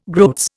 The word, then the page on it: groats: Indian